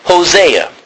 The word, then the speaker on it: Hosea: So, a Greek guy would pronounce it OC. But this is Hosea.